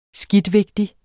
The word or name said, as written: SKIDTVIGTIGE